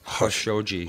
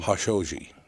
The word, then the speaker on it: Khashoggi: The only one pronounces right then is Anderson Cooper. – Yeah. – He pronounces Khashoggi. – Khashoggi.